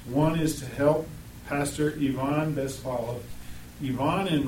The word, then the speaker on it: Ivan: You and I would read it and say Ivan. But the Russian pronunciation is Ivan.